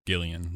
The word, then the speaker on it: Gillian: And then she had to correct him. It's Gillian, like Gillian's Island. So Gillian Jacobs.